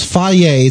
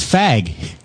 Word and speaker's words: Fage: It's faye. It's pronounced faye. It's Serene Greek yogurt. – It says fag. – It's not fag.